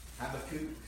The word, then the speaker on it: Habakkuk: Turn to Habakkuk or Habakkuk chapter 2. That's how you pronounce it Obadiah, Jonah, Micah, Nahum, or Nahum. Habakkuk.